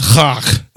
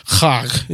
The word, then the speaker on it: Chag: Those particular feasts, those holidays are known as, I'm going to try to do this right, I don't speak Klingon or Hebrew, but it's Chag. That's what it sounded like when I heard it pronounced on YouTube. Chag. It's C-H-A-G.